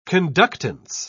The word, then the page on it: conductance: British English